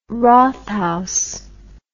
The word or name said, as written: rathaus